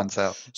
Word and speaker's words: Ancel: Rayman creator Michel Ancel, is that how we pronounce it? – Yeah, Ancel.